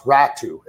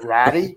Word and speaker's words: Ratu: And there's players that when you're talking about Atu Rat, I believe it's pronounced Ratu. It's Atu Ratu. But, like, I have a tough time, Ezra, not saying Ratty, because it's just sitting there. – It spells it. – Yeah. Anyway, but that, you know, that's how it's pronounced.